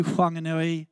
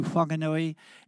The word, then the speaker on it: Whanganui: People, for the very first time since we relocated from Auckland, as some of you know, to Whanganui. It does have a silent H, by the way, down there, local iwi. So we went to Whanganui, and I needed a new doctor.